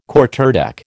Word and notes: Quarterdeck: American English

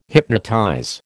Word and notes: hypnotize: American English

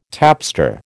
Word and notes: tapster: American English

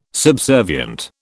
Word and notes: subservient: British English

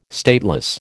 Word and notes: stateless: American English

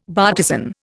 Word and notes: bartizan: Indian

